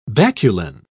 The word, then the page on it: baculine: British English